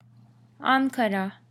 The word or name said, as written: Ankara